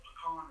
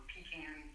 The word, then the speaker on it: pecan: In addition of a lot of pretty large sweet corn operations, and then we're also known for the largest pecan or pecan, depending on where you're from, how you pronounce it.